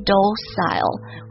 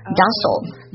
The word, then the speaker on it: docile: 比如说第一个 docile, 它可以读作 docile, 或者 docile, docile, docile, D-O-C-I-L-E, 这个单词它使用词表示温顺的、驯服的。